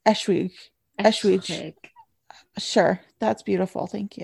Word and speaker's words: Eschwege: She's looking up the pronunciation. Landgrafenschloss Eschwege. There you go. Do that. Ashwiga. Chantalaga Ashwig. Ashwiga. Okay. Ashwiga.